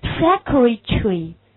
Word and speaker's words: secretary: 那么实际上这个斜着的音就表示这个音可以省略不读，所以那么我们这两个音可以直接读成 secretary， secretary， secretary，读出来就是 secretary， secretary。